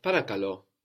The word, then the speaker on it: parakalo: We had parakalo, which meant please, parakalo, se parakalo, please, also the accent on the end.